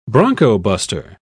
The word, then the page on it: broncobuster: British English